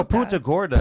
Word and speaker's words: Punta Gorda: You know, Punta Gorda. – Punta back in your pants. – Punta Gordo is only a mispronunciation for saying fat whore.